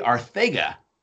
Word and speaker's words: Arcega: And it is, let me make sure I pronounce this right, J.J. Arcega. It's like a Sega, but not.